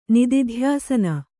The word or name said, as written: ನಿದಿಧ್ಯಾಸನ